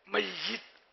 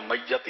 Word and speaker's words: میت: اس کا بھی تلفظ یا کے نیچے زیر ہے، میت ذرا میت ہی بولتے ہیں، سید یا کے نیچے زیر ہے، سید ہی بولتے ہیں ہمارے عام بول چال